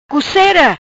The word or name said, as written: Cucera